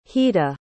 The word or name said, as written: hita